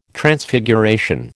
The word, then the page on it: Transfiguration: American English